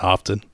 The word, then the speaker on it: often: You often pronounce the T and often? – I don't often, actually.